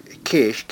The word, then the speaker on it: Kish: This word Cis, really, in the Old Testament is pronounced Kish, K-I-S-H. This is the Greek form of the Hebrew word Kish, and it really should be Kish.